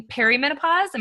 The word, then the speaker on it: perimenopause: The first one being perimenopause. Am I saying that correctly? – You are.